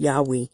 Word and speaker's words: Yahweh: Why it is almost, if not quite certain, that the name was originally pronounced Yahweh.